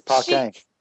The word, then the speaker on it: Paquin: She pronounces her last name wrong. It's Paquin.